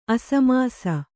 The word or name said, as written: ಅಸಮಿಸಿ